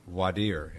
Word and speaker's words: voir dire: And Sherita, I just wanted to Hans said a French term, voir dire, and that is the correct way to pronounce it. That means to see and to speak. A lot of lawyers I know, and this is how I was taught, call it a voir dire, because it's spelled V-O-I-R-D-I-R-E, but it is a French term that's really voir dire, but you'll hear it both ways.